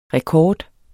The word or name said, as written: rekord